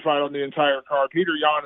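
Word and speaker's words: Petr Yan: And do you call him Peter the way that Dana does, or Petur? How do you say his first name? Because I've heard it pronounced probably five different ways. – Yeah, I think it's actually Piotr Jan. I think it's how he actually says Piotr.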